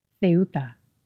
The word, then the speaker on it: Ceuta: And now they're wandering around the town of Ceuta. That's how you say it.